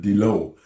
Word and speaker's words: diloo: And so I look at a word like, I wrote a piece called the Dilo, but in Métis it's spelled D-I-L-O-O, but it means water.